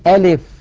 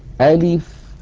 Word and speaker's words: ألف: زمن الفتحة في أ مساو لزمن الكسرة في لي مساو لزمن البقية الحركات، ألف ألف هكذا من غير تكلف.